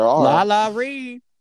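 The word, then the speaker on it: Lala Ri: Lala Reed. – There's no D, girl. – Lala Ree. Oh my gosh.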